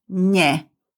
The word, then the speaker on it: ne: To negate, use the prefix ne, pronounce nye, singular.